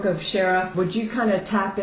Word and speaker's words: Cherith: It's spelled C-H, but it's Cherith is pronounced.